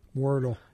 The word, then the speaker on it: Wordle: Wordle.